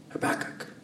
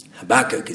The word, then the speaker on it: Habakkuk: That's how they tell me we're supposed to pronounce it. Habakkuk. Habakkuk is all about recognizing the injustice, waking up to it.